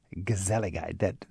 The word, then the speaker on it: gezelligheid: Wild and crazy as Amsterdam appears to the travelers, there is that gezelligheid.